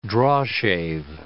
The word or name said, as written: drawshave